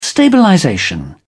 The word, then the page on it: stabilization: British English